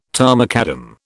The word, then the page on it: tarmacadam: British English